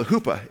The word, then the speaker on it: huppah: Now, so the bridal chamber uh, was called a huppah. H O P A is how you might pronounce it. It's H U P P A H, the Hebrew transliteration.